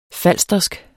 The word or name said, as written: falstersk